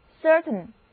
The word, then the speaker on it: certain: concern 非常近似的一个词根是 cert，表示确信、确定，所以在 cert 的后面加上形容词后缀 A-I-N 就构成形容词 certain 确定的、肯定的。这里的字母组合 A-I 不发音，所以跟我一起来念 certain。